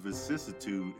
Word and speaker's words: vicissitude: He pronounced it right. – I got the pronunciation, though. – Vicissitus.